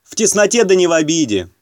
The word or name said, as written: в тесноте да не обиде